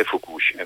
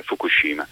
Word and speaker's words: Fukushima: È così, certo, dopo Chernobyl 1986 e dopo Fukushima 2011, peraltro sono contento di sentire da Pio D'Emilia come si pronunci effettivamente perché lo sentiamo Fukushi, Fukushima, Fukushima, insomma, sì. è Fukushima. È Fukushima.